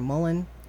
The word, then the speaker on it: mullein: It's either mullein or mullein, which M U L L E I N. How do I pronounce that? Is it mullein or mullein?" – Mullein.